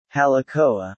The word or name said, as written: Halekoa